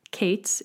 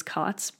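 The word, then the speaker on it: Kaats: I grew up, all of us pronounced my last name Kates. It's K-A-A-T-S, so clearly Kates makes no sense. Any other pronunciation would make more sense. It's Dutch, and so technically it is Kotz, but when my family came here from the Netherlands, America was like, it's gonna be Kates.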